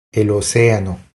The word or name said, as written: El oceano